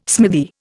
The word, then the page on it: smithy: Indian